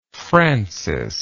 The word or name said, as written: francis